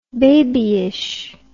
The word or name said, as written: babyishness